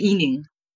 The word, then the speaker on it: Yining: So you can call me Yining.